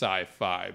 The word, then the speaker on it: SYFY: S-Y-F-Y. It's not sci-fi. It's... – I mean, in the literalist form, it is... pronounced sci-fi, but it's...